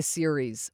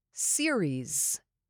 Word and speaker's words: series: Next one, series. Emphasize the first syllable here.